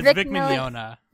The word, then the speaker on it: Mignogna: And watch the dub, because you got Vic Milanagna in it. He plays Dark. – Wait, how do you say his name? – It's Vic Mignogna.